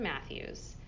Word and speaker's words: Mathewes: Well, uh, my my Instagram and my website and Facebook and TikTok are all the same thing, and it's Jocelyn Mathewes either .com or whatever, but I have to spell it for you because my last name is weird.